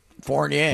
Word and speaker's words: Fournier: Fournier.